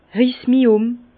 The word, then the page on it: Riss-mi-um: Bas Rhin; Herrlisheim